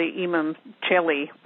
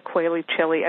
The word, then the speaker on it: Imum Coeli: You know, I like to say that the nadir um, or the Imum Coeli, or uh, people pronounce it Quaili Chili. I mean, you hear it.